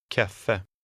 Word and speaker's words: kaffe: In number 12, the final E is pronounced very slightly. Try to read number 12. – Kaffe.